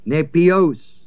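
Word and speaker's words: nepios (νήπιος): And the babes here is the Greek word nepios, N-E, long E, P-I-O-S.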